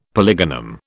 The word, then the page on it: polygonum: British English